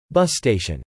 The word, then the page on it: bus station: /ˈbʌs steɪʃn/